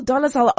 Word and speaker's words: Dolezal: So it's the women's news, and I thought we should start with uh, Rachel Dolezal. Is that how you say it? – Rachel Dolezal. Oh, yes.